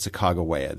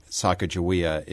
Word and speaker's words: Sacagawea: Well, I pronounce it Sacagawea. This is something that people argue about. The reason many people pronounce it Sacagawea is that William Clark, in his manuscript journals, wrote her name in several different ways.